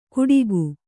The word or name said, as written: ಕುಡಿಗು